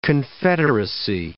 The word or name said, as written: confederacy